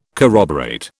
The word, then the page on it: corroborate: British English